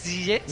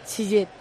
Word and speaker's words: Ziyech: No se pronuncia en la E, no es Ziyech. – No es mal, pero ¿cómo es bien? – Ziyech.